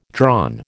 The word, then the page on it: drawn: American English